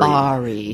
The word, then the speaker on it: Bari: you'll see that they have Berry, and of course Berry. – Bari. – Bari, thank you.